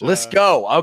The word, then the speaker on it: Litzkow: You would think from the way it's spelled, it's actually Litzkow, which Litzkow. Okay. Sorry. Litzkow. – Yeah, the W is just there to mess with people.